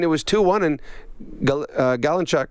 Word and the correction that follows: Galchenyuk: how do you say his name again? – Galchenyuk. – Galchenyuk.